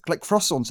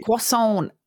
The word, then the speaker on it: croissant: Because I guess like other layered foods, like croissants are technically just like millions of layers of like doughy bread, right?